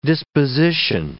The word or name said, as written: disposition